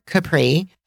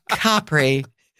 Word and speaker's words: Capri: And most people, Americans, pronounce it Capri. And I want you to know that that's how you call the pants that are below your knee. Okay? Right. So Capri is the island. All right? That's the correct pronunciation.